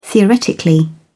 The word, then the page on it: theoretically: British English